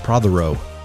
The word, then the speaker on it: Prothero: Prothero. Is that right? – Did great. Prothero. All three syllables.